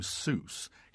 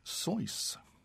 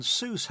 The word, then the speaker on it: Seuss: Incidentally, the name Seuss, Geisel's mother's maiden name, should ideally be given the German pronunciation that his family background would suggest. One of his collaborators, Alexander Laing, wrote a helpful verse. You're wrong as the deuce, and you shouldn't rejoice. If you're calling him Seuss, he pronounces it Soys." He did adopt the pronunciation Seuss, however, as it evoked a character possibly advantageous for an author of children's books to be associated with Mother Goose.